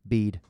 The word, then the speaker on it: Bede: Bede.